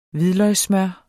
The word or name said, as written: hvidløgssmør